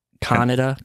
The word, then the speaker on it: Kaneda: Did they pronounce it like Canada in the dub? – No, it's like... Kaneda? Kaneda? Kaneda! Yeah.